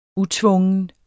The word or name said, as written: utvungen